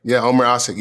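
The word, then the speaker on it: Ömer Aşık: In his last name, there's like a letter I've never seen before, but I think you pronounce his name Omar Asik. – Oh, yes, yeah. He played for the Rockets. Yeah. Omar Asik, yes.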